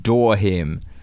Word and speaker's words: Durham: Also of note, the adjacent town I live in now, Durham, is pronounced Dorham.